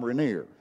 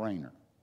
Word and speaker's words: Rainer: As Lynn Parrish used to say, my Sunday school director up at First Pikeville, he called him Thom Rainier because his name is spelled Thomrainer. And so he called him Thom Rainier. I guess it looks like that's the way you pronounce it, but it's Tom Rainer.